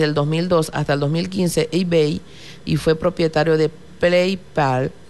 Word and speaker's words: eBay: También un día como hoy, 3 de septiembre, podemos mencionar que se funda eBay, pronunciación en inglés eBay.